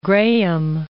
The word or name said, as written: Grahame